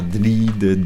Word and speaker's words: DLID: Non, après, pour mon nom, qu'on ne sait pas comment le prononcer, si on doit dire DLID, D-L-I-D, D-L-I-D en anglais ou quoi...